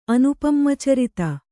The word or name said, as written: ಅನುಪಮಚರಿತ